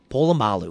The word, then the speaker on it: Polamalu: Polamalu.